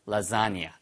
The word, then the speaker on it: lasagna: To your lasagna. Lasagna. L-A-S-A-G-N-A에서 발음은요. 라자니아처럼 발음돼요.